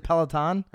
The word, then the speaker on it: Peloton: Is it Peloton? – It's Peloton. You got Ferry pronunciation. He says Peloton as well.